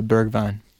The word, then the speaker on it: Bergwijn: Uh, Bergwijn.